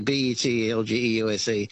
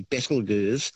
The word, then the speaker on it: Betelgeuse: It's a fun way of pronouncing the name, actually, Betelgeuse. But the correct pronunciation is said to be Betelgeuse, which comes from an old Arabic name, meaning the armpit of the giant.